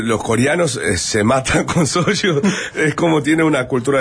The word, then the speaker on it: soju: pero el soju.